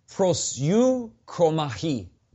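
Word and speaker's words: prosyukomahi (προσεύχομαι): The original word there for both pray and praying that's used in our English text is pronounced prosyukomahi. It's a Greek word.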